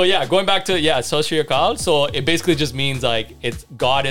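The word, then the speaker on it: Sat Sri Akal: So it's basically Sashriyaka. So SAT, that's the first word. SRI, the yeah. second word, and then Akal, AKAL, Shashri Akal.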